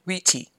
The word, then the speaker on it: rete: And on top of that is another plate called a rete, pronounced riti, sorry, on which there is a chart depicting the brightest stars in the sky. The idea is to rotate the riti